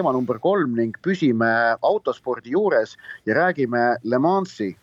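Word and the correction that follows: Le Mans: kas ma hääldasin õigesti, Joosep? – no Le Mani.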